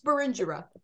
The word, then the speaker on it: Berengera: Berengera Caswell, correct? – Close, Berengera.